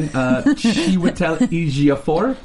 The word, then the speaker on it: Chiwetel Ejiofor: This morning, Chiwetel Ejiofor.